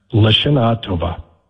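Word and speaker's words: Lashana Tova (לשנה טובה): Lashana. Here, Lashana. Let's pronounce. Lashana Tova. – Lashana Tova. Lashana. – There you go.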